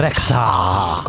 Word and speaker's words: Rexha: Si pronuncerà così, Rexha. – Rexha con l'H. – Eh sì, perché va sentita la H, se no che la metti a fare, scusa.